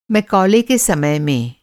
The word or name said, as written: थॉमस मैकाले